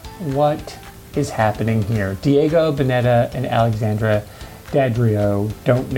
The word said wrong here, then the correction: Daddario: know what, I'm sure I'm saying her name wrong, sorry, fans of Daddario? Alexandra Daddario. Let's go with that. There's a double D.